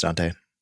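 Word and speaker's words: Daunte: And what I'm talking about is I hope I pronounce this right. I think it's Dante, right? – Yeah, it's Dante.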